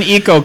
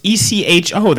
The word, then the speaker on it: Ecco: When Eco comes out, maybe I'll try Convergence again. – The Dolphin? – But no promises. No, that's Echo. – I always call them Eco. – It's ECHO. That's pronounced Echo.